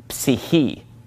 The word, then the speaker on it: psyche: Well, psyche comes from the Greek word psyche. Psyche, and they do pronounce that PS at the beginning of the word psyche.